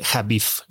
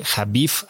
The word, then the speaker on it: Habif: Hoy tengo como invitado a Daniel Jabif. Lo puedes seguir en Instagram como arroba Daniel Javif. Javif se escribe con una H y una B alta.